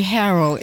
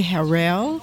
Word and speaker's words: Harrell: It depends. Some people say Harold. If you're from Israel, you say Harel because it is a Jewish word.